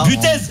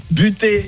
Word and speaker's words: Butez: Butez. Oh, bah oui, mais c'est pas comme ça qu'on prononce. – Buté.